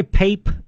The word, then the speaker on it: Pape: Try Pape, P-A-P-E, although I think you actually pronounce it Pape, but Pape's over in Seguin.